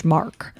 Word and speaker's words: Marqe: It's actually spelled M-A-R-Q-E, but it's pronounced Mark.